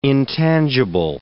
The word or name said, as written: intangible